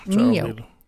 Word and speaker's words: NIO: Uttalar man det Nio eller Nio eller Nio? – Nio. Mm. – Får man säga Nio också? – Ja, man får säga Nio.